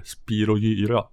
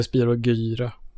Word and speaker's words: Spyro Gyra: officiella musikens maktuttalet är spirogyra. Ja, jag säger spirogyra. Ja, men... gy är väl, g före y blir väl j på svenska, väl? Ja, ja kanske det. Gymnastik. – Mm. Ja. – Spirogyra.